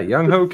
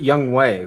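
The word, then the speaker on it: Younghoe: Yeah, I did know that, but they pronounce it Young Way, which I don't know how the fuck they get Way out of Ho.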